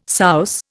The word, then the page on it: souse: Indian